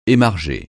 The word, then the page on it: émarger: francais